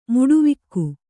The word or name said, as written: ಮುಡಿವಕ್ಕಿ